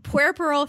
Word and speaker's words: puerperal: Puerperal fever, the same thing that killed Henry VIII's wife, Jane Seymour.